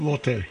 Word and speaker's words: Lotte: Is it Lotte? Is that how you say it? – Lotte. – Lotte.